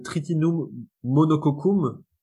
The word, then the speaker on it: Triticum monococcum: c'était le Tritinum monococcum.